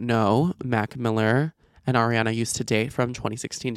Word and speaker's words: Ariana: If you guys, I don't know why I say her name Arna. I say it like A-R-N-A. Ariana. I need to fully pronounce that.